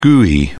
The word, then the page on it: gui: British English